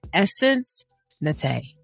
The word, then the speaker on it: Essence Natay: And I just want to welcome into the studio Essence Natay.